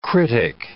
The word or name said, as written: critic